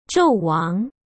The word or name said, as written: zhouwang